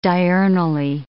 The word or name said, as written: diurnally